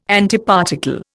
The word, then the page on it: Antiparticle: Indian